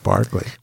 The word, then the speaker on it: Berkeley: I'd love you to chime in, and I'd also like you to explain why the word Berkeley is pronounced Barclay.